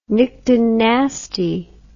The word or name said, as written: nyctinasty